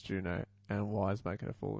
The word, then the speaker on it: Juneau: The city and borough of Juneau is pronounced Juno.